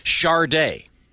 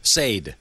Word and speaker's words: Sade: Sharday. – Yeah. – Or as I like to call her, Sade.